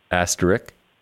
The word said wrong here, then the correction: asterisk: I'm sorry, asterisk. – Okay, well, so two things just happened there. At first, you said asterisk without an S, and then you said asterisk with an S.